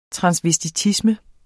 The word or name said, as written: transvestisme